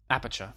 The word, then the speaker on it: Aperture: Ja, det hade ju kunnat passa rätt bra ihop med till exempel Aperture eller hur uttalar man det nu? Aperture. Var det rätt?